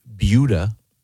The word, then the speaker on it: Buda: No, that's how it's pronounced. – Okay. – Well, Jason, is it B-U-D-A?